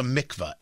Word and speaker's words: mikvah: So they, in this synagogue, they built a tunnel, and the tunnel is connected to something called a mikvah, M-I-K-V-A-H.